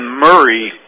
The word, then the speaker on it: Murray: Looks like number 7, Lincoln Murray, is going to be coming into the backfield for East Atchison.